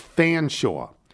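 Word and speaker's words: Featherstonehaugh: Featherstone Haw is an English family. It's Fanshawe.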